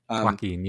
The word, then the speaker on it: Joaquin: I wish I was so smart, but Joaquin, is that right? Is that how you say his name? – Joaquin, yeah.